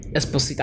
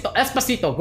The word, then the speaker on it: Esposito: Esposito.